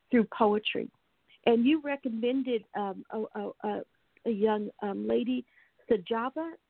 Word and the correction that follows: Sajabu: Am I pronouncing it correctly? – Sajabu. Yeah, grandmother Sajabu.